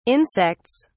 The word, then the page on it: insects: British English